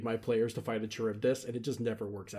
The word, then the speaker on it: Charybdis: Know of it as being pronounced Charybdis.